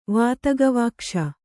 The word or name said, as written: ವಾತಗವಾಕ್ಷ